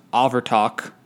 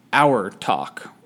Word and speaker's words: Abhartach: It's spelled with B's, and it's spelled A-B-H-A-R-T-A-C-H. From what I can tell online, it's pronounced Avertok, but I've also seen that it was pronounced Our Talk or Our Tog.